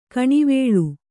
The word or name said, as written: ಕೆನೆವಾಲ್